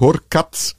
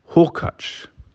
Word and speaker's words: Hurkacz: Hubert Horkatz. Die korrekte Aussprache lautet Hubi Hurkacz. Hubi und Iga Swiatek.